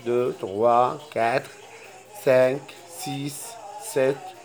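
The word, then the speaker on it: sept: أول رقم صفر اسمه زيغو يعني صفر، واحد يعني أن، اثنين، دو، ثلاثة، تغوا، أربعة، كاتغ، خمسة، سانك، ستة، سيز، ستة، سيز، سبعة، ست، البي لا تنطق، ست،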